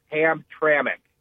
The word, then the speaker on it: Hamtramck: The name of the city you're referring to is Hamtramck. – That's okay. Hold on a minute. That's where all the robots are replacing all the GM workers? – That's correct. That's how it's pronounced.